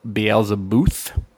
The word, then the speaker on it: Beelzebuth: It is called Beelzebuth. That's how I say it. It's Beelze, B-U-T-H.